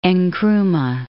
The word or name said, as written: Nkrumah